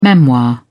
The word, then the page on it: memoir: British English